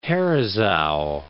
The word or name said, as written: Herisau